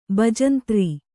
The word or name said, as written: ಬಜಂತ್ರಿ